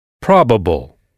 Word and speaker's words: probable: Probable. – Probable. Là encore, on insiste en anglais sur la syllabe qui précède cette terminaison, donc probable. On frappe en quelque sorte sur cette syllabe, probable, probable.